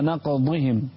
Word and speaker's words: نقضهم: فبما نقضهم ميثاقهم وكفرهم بآيات الله وقتلهم الأنبياء انتبه إلى الضاد فبما نقضهم. – نعم. – لا تنطق هدال.